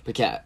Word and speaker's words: Paquette: Paquette.